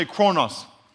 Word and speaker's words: chronos: Everyone say chronos. Say chronos. it's spelled C-H-R-O-N-O-S, but it's really, the O's are, Omicron in Greek is or, like offer, and kairos.